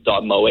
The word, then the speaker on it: moe: Dot M-O-E? – Yeah. – Dot Mo? – It's pronounced Moe, but yeah.